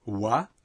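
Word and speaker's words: wa (は): Le marqueur de sujet « wa » se prononçait et s'appelait autrefois « ha ». La prononciation a changé graduellement pour devenir « wa », mais on l'appelle toujours « ha ».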